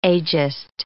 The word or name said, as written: agist